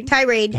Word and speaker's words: tirade: And then in the middle of her little tirade, how do you say that word? – Tirade.